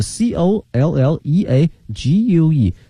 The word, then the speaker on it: colleague: 大学呢是 college， C O L L E G E，而这个呢是 C O L L E A G U E，发音不一样哈。